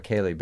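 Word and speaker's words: céilí: So if you have a Kaylee band, it's going to be the sort of band that specializes in that kind of, exactly.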